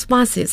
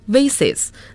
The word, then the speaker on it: vase: There are glass vases and so on，都是玻璃花瓶还有诸如此类的东西。那么刚才周老师的读音是 vases，那么还有一个发音是 vase，一个是 vase，一个是 vase，英音 vase，美音 vase。